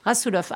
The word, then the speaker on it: Rasoulof: Rasoulof.